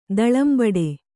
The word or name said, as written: ದಳಂಬಡೆ